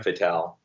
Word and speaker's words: fatale: Yeah, they're almost like as I flip through your book, am I pronouncing it right? I said fatale. Is that? – Like, yeah, fatale. It's like femme fatale.